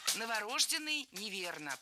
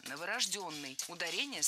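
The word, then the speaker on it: новорождённый: Новорожденный неверно, правильно? Новорожденный. Ударение ставим на «ё» в 4-м слоге. Только новорожденный.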